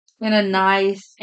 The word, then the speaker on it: Ananias: Ananias. – Ananiasis. – Okay. No, there's no sis. Ananias. – Ananias.